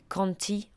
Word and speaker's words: Conte: En fait, je pense que ça se prononce Jack Conti en anglais. Ça s'écrit comme un conte en français.